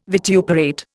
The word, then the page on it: vituperate: Indian